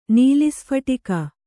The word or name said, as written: ನೀಲಿಸ್ಫಟಿಕ